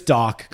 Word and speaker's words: Dach: So one last point on the drug topic, I guess, of the NHL was Kirby Dach. Is it Dach? Is that how you pronounce it? – I believe it's Doc. Kirby Doc. Okay.